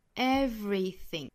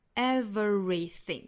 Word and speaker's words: everything: 我们再来看 everything 这个单词，它的音节其实是三个音节啊， everything, 哒哒哒，三个音节。但是我们可能听到过这个词被读成四个音节的情况， everything, 这样其实也是可以的， everything。那一般来说我们都会读成它三个音节的情况，就是中间会省掉一个小刷音哈，会省掉一个的音。